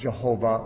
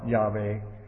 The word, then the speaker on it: Yahweh: And when you add the vowels from Adonai to this, you can pronounce it Jehovah or Yahweh. Yahweh, and so it's alternately.